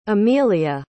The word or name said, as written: amellia